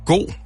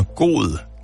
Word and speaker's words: god: L-A-K-S. Laks. – Fisk. – Du siger fisk. Ord nummer to. God, eller som man også siger, hvis man udtaler alle bogstaverne, god, G-O-D. – Dårlig. – Ord nummer tre, lede, L-E-D-E, lede.